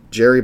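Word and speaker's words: Gerry: And I keep looking at their quarterback and Jerry Bohannon. I believe that's how it's pronounced, not Gary Bohannon.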